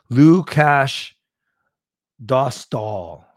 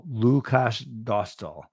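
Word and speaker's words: Lukáš Dostál: Lukash Dostal. stall Lukash Dostal. Dostal.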